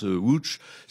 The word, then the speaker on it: Łódź: Ça s'écrit Lodz, mais ça se prononce Łódź.